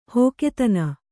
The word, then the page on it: ಹಗೆತನ: hagetana